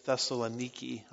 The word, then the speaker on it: Thessaloniki: You've learned your whole life you've mispronounced Thessalonica. They pronounce it Thessaloniki, I think.